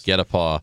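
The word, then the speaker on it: guet-apens: G-E-T-A-P-E-A-U-X. – No. But you were a little bit closer. It's G-U-E-T-A-P-E-N-S. But it's pronounced get a paw.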